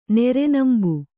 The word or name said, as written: ನೆರೆನಂಬು